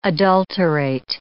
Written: adulterate

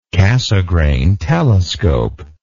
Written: cassegrain telescope

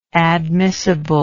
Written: admissibleness

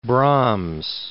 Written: Brahms

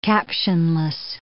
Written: captionless

